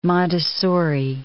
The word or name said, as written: Montessori